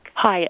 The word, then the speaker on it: Hayek: Am I saying her name right? Selma Hayek? – Hayek. – The actress? – Hayek. – Yes, Hayek. Okay.